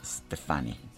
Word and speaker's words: Stefani: En el caso de la cantante que estamos escuchando, Gwen René Stefani, la pronunciación es Stefani.